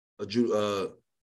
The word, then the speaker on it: Ujgare: Ujgare.